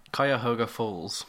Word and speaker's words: Cuyahoga Falls: Cuyahoga Falls. – Hey, that's it. – That's it. – Yeah. – Oh, okay. – Yeah. – Cuyahoga Falls.